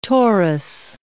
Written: Taurus

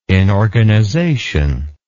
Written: inorganization

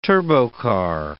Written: turbocar

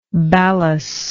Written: ballas

